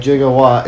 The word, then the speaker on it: gigawatt: It is a correct measurement, but it's pronounced, it's a hard G, so it's jigga, like gigawatt. It's not with a J.